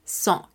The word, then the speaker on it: cinq: En el número 5, C-I-N-Q, lo que estamos haciendo es prácticamente una nasalización. ¿Por qué? Porque tenemos la I antes de la N, entonces es song. En el número 6, esta X que se queda al final porque se escribe S-I-X esta X al final se convierte en el sonido de una S prácticamente, cis.